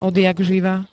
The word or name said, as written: odjakživa